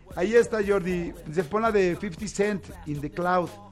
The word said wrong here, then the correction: In the Club: Ubica In the Club, sí, claro. – Ah, In the Club, perdón, sí, es pronunciación sí.